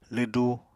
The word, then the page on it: lwdu: Apatani